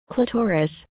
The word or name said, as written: Clitoris